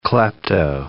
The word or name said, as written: klepto